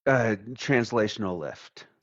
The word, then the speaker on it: translational lift: I want to focus on the term translifting. Am I pronouncing it correctly? – Translational lift. – Translational lift.